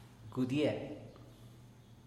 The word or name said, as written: gudie